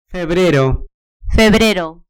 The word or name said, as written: febrero